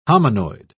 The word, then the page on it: hominoid: British English